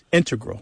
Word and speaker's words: integral: Yeah, but both correct, just to clarify here, both correct, but the mathematicians and math-using scientists are more likely to say integral with the stress on the first syllable.